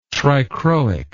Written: trichroic

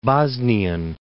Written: Bosnian

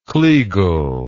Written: kleagle

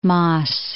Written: Maes

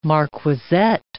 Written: marquisette

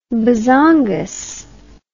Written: bezongas

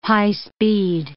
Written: high-speed

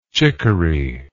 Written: chiccory

